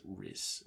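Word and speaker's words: Rhys: I hope I'm saying that right. It's like R-H-Y-S. I think that's Reese.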